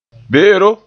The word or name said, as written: be'ero